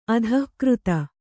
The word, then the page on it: ಅಧಿಕೃತ: adhikřta